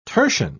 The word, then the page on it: tertian: British English